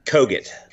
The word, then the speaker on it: Kogut: It's Kogut.